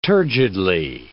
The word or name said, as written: turgidly